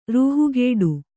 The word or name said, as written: ರೂಹುಗೆಡು